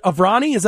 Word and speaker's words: Aavrani: Is it Aavrani? Am I pronouncing that right? – Nailed it. Exactly.